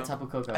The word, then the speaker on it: Tapu Koko: Is that how you say it? – Tapu Koko? Yeah, Tapu Koko.